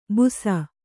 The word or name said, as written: ಬುಸ